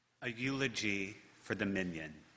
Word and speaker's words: minyan: But the Hebrew word minyan, pronounced minion in English and spelled with a Y-A-N, are the 10 Jewish adults required for communal worship.